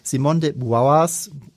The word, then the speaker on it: Simone de Beauvoir: Und über Simone de Beauvoirs, wie spricht man das richtig aus? – Simone de Beauvoir.